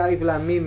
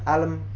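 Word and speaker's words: alif lam mim (الم): Karena dibacanya dibaca huruf. Bukan dibaca alam. Atau alam ro, alamr. Enggak, tapi bacanya alif lam mim ro.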